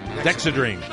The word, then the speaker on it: Dexedrine: It was dextrodine. Am I pronouncing that correctly? – Dexedrine? – Yeah. – Dexedrine.